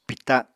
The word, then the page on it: pìta: apatani